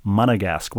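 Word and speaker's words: Monégasque: And he highlights that so I can give the French pronunciation. Monegasque, what a great appellation for somebody who is from Monaco, Rebecca.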